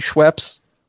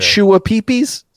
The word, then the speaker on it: Schweppes: Did we talk about the clip that went viral a couple weeks ago about some dude on TikTok mispronouncing Schweppes? – We didn't. I know of this. We didn't talk about it on the show. – We did not. – He said Shua Peepees.